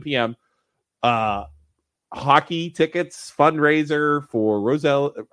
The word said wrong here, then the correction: Rosalie's: I never say that right. – Rosalie's Forever Footprints.